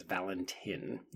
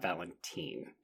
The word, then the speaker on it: Valentin: It is Valentin, not Valentine. – Oh, really? – Mm-hmm. – Okay. – There's no E. – No, but just so you know, in Spain, the I is pronounced E.